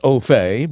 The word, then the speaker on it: au fait: It is au fait, pronounced au fait, but spelled A-U-F-A-I-T.